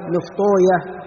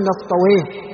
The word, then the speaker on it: نفطويه: نعم، قال: وعبد الله بن أحمد بن شبوية، وهو الخزاعي، وهو الخزاعي، وهذه الأسماء التي في نهايتها مثل نفطويه وسيبويه وراهوية، فهذه الأسماء أهل الحديث ينطقونها شبوية، نفطوية، سيبوية، لأنه جاء عن إبراهيم النخعي بأن ويه اسم شيطان، أن ويه اسم شيطان، هكذا ذكر عن، نقل عن إبراهيم النخعي، نقل ابن حجر، فأهل الحديث ينطقون هذه الأسماء شبوية راهوية نعم نفطوية، ولا يقولون نفطويه أو سيبويه نعم.